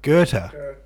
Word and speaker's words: Goethe: Goethe. – Goethe. Okay. G-O-E-T-H-E is pronounced Goethe.